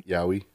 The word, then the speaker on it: yaoi: How do you pronounce that? Is it yaoi? – Yaoi.